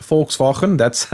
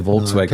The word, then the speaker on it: Volkswagen: You pronounced it wrong, though. – Did I? – But I just... Yeah, well, it's not okay, sure. I'm being a bit cruel because I can pronounce it's actually pronounced Volkswagen. But I understand that people can't get that sound, so they say Volkswagen. – No, can't do it. – But it's it's hilarious though hearing people say Volkswagen.